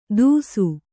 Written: ತುಸು

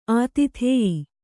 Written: ಆತಿಥೇಯಿ

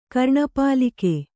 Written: ಕರ್ಣಪಾಲಿಕೆ

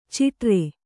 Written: ಚಿಟ್ಟಿರಿ

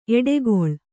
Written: ಎಡೆಗೋಲ್

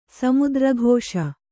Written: ಸಮುದ್ರಘೋಷ

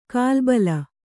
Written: ಕಲುಬಿ

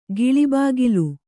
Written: ಗಿಳಿಬಾಗಿಲು

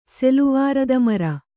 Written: ಸೆಲುವಾರದಮರ

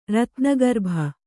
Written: ರತ್ನಗರ್ಭ

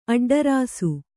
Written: ಅಡ್ಡರಸು

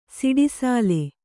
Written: ಸಿಡಿಸಾಲೆ

